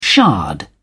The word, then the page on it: shard: British English